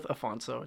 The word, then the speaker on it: Afonso: Yeah, there's a couple different pronunciations of it that I'll get from my, my grandmother or my father, but go with Afonso.